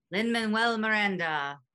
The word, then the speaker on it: Lin-Manuel Miranda: Please tell me if I butcher this guy's name. Lin-Manuel Miranda? – Lin-Manuel Miranda.